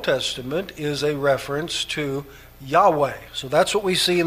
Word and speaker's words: Yahweh: but more recently has generally been considered to be pronounced as Yahweh. When you hear those two different terms, you need to understand that's just basically a greater understanding of how Hebrew works and an understanding of how Hebrew was pronounced. And so what for a long time was considered to be Jehovah, we now recognize as probably something closer to Yahweh, though nobody's absolutely certain, but it's certainly closer to the Hebrew pronunciation.